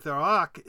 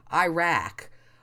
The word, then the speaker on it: Iraq: found that pronouncing the second syllable rack, Iraq,